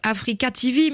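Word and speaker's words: AfreecaTV: Alors, ça se prononce Africa TV, mais rien à voir avec le continent africain. – Rien à voir avec le continent africain. C'est le free de libre.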